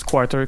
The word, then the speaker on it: QRTR: Ça s'écrit Q-R-T-R, mais ça se prononce Quarter comme un, un quart en anglais. Comme un quart de pièce.